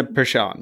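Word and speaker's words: Persson: Nina Pashan, yes.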